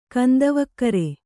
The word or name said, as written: ಕಂದವಕ್ಕರೆ